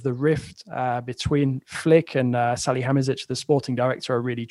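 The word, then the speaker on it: Salihamidžić: Salihamidzic.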